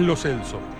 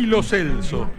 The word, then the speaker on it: Lo Celso: Hernán Feller hoy tiene, como todos los relatores, cruel desafío de haberse enterado hace 2 horas que es Lo Celso. Porque los jugadores, papel en mano, dijeron ante la cámara de FIFA cómo se pronuncian sus nombres. Y el número 11 argentino dijo Giovanni Lo Celso. O sea que pronunciamos mal su apellido más de 10 años.